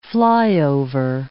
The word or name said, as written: flyover